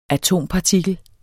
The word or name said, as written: atompartikel